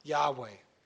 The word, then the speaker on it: Yahweh: It says, the true pronunciation of the name YHWH was never lost. Several early Greek writers of the Christian church testified that the name was pronounced Yahweh.